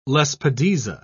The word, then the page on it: lespedeza: British English